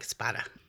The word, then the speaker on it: Spada: I was going to say, thank you for pronouncing it that way, because my husband says it's Spada. Everybody else, even Surrey, says Spada. And then I found out that back in the day, one brother was mad at the other brother generations ago and split off and changed the pronunciation. So it is Spada because it's Italian. It comes from Espada. So I like Spada.